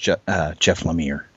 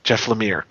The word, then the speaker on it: Lemire: And I believe that's pronounced Jeff Lemire. – Jeff Lemire, correct.